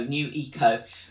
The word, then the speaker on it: Nueco: There's one called New Eco, that's spelled N-U-E-C-O, New Eco.